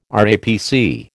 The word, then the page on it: RAPC: American English